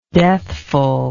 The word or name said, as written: deathful